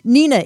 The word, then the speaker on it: Hnina: Nina is H-N-I-N-A, but pronounced Nina, the H is silent.